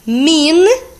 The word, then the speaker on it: mean: Mean é quer dizer, significa. Então, eu escrevo M, E, A, N, man, e eu pronuncio mean, ok?